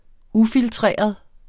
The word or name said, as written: ufiltreret